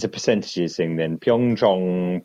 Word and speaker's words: Pyeongchang: He sent me a link to something saying that Pyeongchang is not pronounced Pyeongchang. It's the latter half of it rhymes with gong, so it's really Pyeongchang.